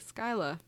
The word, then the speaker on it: Scylla: Stories of the Phylicoteras are probably inspired by the Greek legend of, how do you say that, Scylla? – That's what I'd say. – Okay, Scylla. A huge...